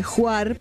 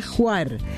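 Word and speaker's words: Hvar: Nos dice que Juar, porque se pronuncia así, en Croacia, es una de las islas más grandes y hermosas que tiene Croacia. Se pronuncia Juar.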